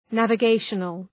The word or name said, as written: navigational